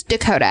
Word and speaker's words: Dakota: Wait, so your name is Dakota. Am I saying that right? – Yeah, it's Dakota with a D.